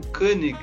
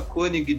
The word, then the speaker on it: Konig: Pois é, a pronúncia no alemão mesmo seria algo como Koenig, mas eu nunca enchi o saco a esse respeito. Então, o pessoal fala Koenig direto, é tranquilo.